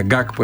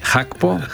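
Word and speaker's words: Gakpo: Αυτοί έρχονται χωρίς το Χάκπο, που το λέγαμε χρόνια Γκάκπο εμείς, ενώ προφέρεται Χι Χάκπο, Πεκτούρα Πεκταράς.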